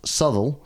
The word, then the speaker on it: Southwell: Anyway, so he decided to take shelter with some of his Presbyterian allies in Southwell in Nottinghamshire, which, if you're from Nottinghamshire, is pronounced Southall, so that's a hat tip to y'all.